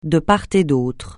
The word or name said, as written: de part et d'autre